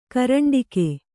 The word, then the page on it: ಕರಂಡಕ: karaṇḍaka